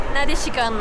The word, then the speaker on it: Nadashicon: Nadashicon, est-ce que c'est comme ça qu'on le prononce? – Nadashicon. – Nadashicon? – Oui. Con de convention.